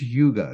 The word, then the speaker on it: hygge: It sounds like, remember the old submarines? Hygge, hygge. Well, this is hygge. It's spelled H-Y-G-G-E.